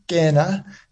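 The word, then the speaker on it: Kena: It's a Sesotho word, Kenna. It means to welcome, to join, the process of welcoming in.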